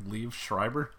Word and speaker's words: Liev Schreiber: Liev Schreiber? – Yes.